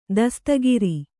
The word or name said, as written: ದಸ್ತಗಿರಿ